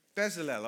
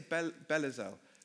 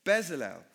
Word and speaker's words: Bezalel: So here we've got this guy, Bezalel. Did I say his name right? Bezalel. I always say Belazel. Bezalel.